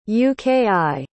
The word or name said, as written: uki